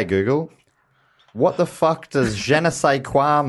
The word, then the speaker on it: je ne sais quoi: How do you say, je ne sais quoi? – Yeah, there it is.